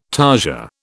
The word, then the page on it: tarsia: British English